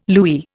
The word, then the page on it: louis: Indian